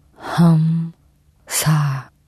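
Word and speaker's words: хамса: Кстати, «хам» говорится мягко, открыто. «Хам». А не как, если бы вы в сердцах припоминали бывшего парня, например.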